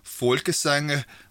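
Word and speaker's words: Folkesange: I'm going to have to do the proper Danish pronunciation, so something like Folkesang. Sorry, good people of Denmark, I love yous, and I think you're great, but I'm trying to pronounce it properly, right?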